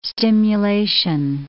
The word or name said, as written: stimulation